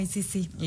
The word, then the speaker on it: Isisi: Ajá, se escribe ISISI. – Sí, un juego de palabras para que justo suene como ICC.